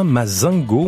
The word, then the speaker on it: Mazingo: C'est une bonne ambiance pour accueillir ce trio franco-américain, Mazingo, on va le prononcer à la française.